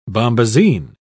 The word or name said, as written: bombazine